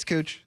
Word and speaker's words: Couch: It's not Couch Street. – Yeah, it's Cooch. There you go.